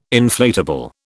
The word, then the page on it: inflatable: British English